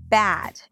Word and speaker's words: bad: Today we're going to talk about the vowel pair bed, bad, right? Eh, ah. As you can hear, these are two different vowels, but they're often pronounced the same by non-native speakers.